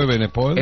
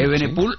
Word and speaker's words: Evenepoel: El segundo fue Renko Evenepoel, ¿se dice así? ¿Sí? – Sí, Renko Evenepoel. – Evenepoel sí.